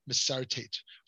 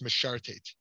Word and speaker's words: Mesharteit: One aspect of preparing hides to be cut constitutes the 29th malacha of Mesharteit, Mesharteit, sometimes pronounced Mesharteit.